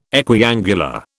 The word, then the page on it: equiangular: British English